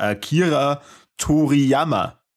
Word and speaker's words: Akira Toriyama: Der Akira Toriyama.